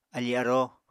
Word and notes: afí-aróo: Apatani

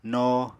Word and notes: no: Apatani

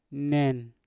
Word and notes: nen: Mpur

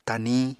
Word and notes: taniñ: apatani

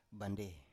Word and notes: bandei: Biak

